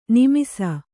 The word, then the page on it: ನೆಮ್ಮಿಸು: Kannada